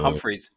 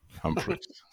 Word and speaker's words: Humphreys: Ähm, als Ersatz kam Bashir Humphreys aus England. Äh, kann das sicherlich tausendmal besser aussprechen, zumindest den Nachnamen. – Äh, Humphreys. Humphreys.